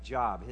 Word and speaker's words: Job: It's spelled J-O-B, so it's not a guy who was employed, like Job. His name is Job, right?